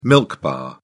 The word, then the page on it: milk bar: British English